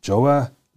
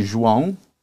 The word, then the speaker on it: Joao: Now, my guest in the studio today, his name is Joao Zoio. No, I'm mispronouncing that. How do you really pronounce your first name? – How do I pronounce it? It's actually Joao. But yes. Everybody knows me as Joa.